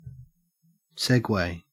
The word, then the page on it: segue: Australian English